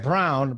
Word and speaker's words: Braun: Christian Brown is spelled Braun, but it's Brown.